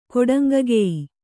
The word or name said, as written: ಕೊಡಂಗಗೆಯಿ